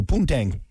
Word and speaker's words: poontang: I've said it now, so poontang. Let's see. – How do you spell that? P-U-N? – That's P-O-O-N. Tang. Tang. – Oh, P-O-O-N? – Yeah, yeah. Like moon, but with a P.